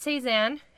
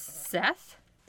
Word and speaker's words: Szeth: And it starts out with not Cezanne. His name is Seth. – Spell it. – S-Z-E-H. – Close. S-Z-E-T-H.